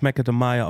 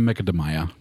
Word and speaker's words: Macadamia: Das wird ja eigentlich Macadamia ausgesprochen. Ja, auf eine Macadamia.